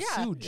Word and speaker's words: Suge: Is that how you say his name is Suge? It's spelled Suge. – Yeah, Suge. – Yeah, like sugar. – Like sugar. Okay. – Yeah. Suge Knight. Yep.